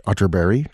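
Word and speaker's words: Atterberg: It's spelled Otterberg, but pronounced Otterberry, just in case you were Googling.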